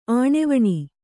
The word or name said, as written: ಆಣೆವಣಿ